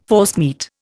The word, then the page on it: forcemeat: Indian